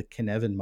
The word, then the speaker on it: Cynefin: There's a model that I really love called the Kinevin model. I'll spell it because it's Welsh and the spelling has no connection to the pronunciation. C-Y-N-E-F-I-N.